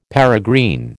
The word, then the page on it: peregrine: American English